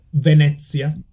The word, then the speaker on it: Venezia: So Venezia.